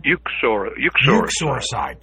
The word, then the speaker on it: uxoricide: and that is oxoricide. Am I saying that right? Uxoricide? – Uxoricide.